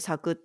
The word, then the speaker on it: 笑: おしゃれよね、うん、あ、笑うと書いて咲くってことね。